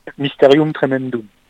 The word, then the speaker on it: Mysterium Tremendum: Mysterium, Tremendem, je sais pas si je le prononce bien. – Bah non, mais c'est pas grave. – Alors vas-y, prononce-le pour nous, enfin pour moi. – Mysterium Tremendum. – Tremendum, ok.